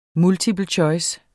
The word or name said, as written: multiple choice